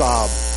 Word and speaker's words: Sahib: except it was pronounced Saab in Bengal, where we lived.